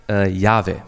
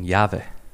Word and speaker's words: Yahweh: Und es ist so, dass dieser Name so wenig ausgesprochen wurde, dass man heutzutage gar nicht mehr so genau weiß, wie der eigentlich ausgesprochen wird, das auch ein bisschen lustig ist. Aber wahrscheinlich heißt dieser Name Yahweh.